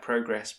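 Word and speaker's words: Prograis: I know his surname sounded like Progress, but it's actually pronounced Progre.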